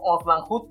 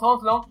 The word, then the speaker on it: Onslaught: Que por cierto, yo lo pronunciaba como Oslan Hood porque nunca lo había escuchado hasta el juego de maquinitas. Cubriríamos Onslaught,